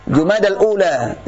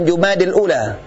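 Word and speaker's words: Jumadal ula (جمادى الأولى): Bukan Jumadil. Salah bacaan Jumada. Jumadal ula. Jumadal akhir. Bukan Jumadi. Hada khata. Jadi Jumadal ula. Jumadal akhir. Bukan Jumadil ula.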